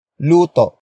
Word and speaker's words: luto: Magluto is spelled as M-A-G-L-U-T-O. It is a command to cook something. Magluto comes from the root word luto. Luto. Luto. Which means a cooking or a cuisine.